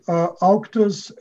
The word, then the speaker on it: Auctus: That is correct. Auctus, A-U-C-T-U-S, is the name of the publisher.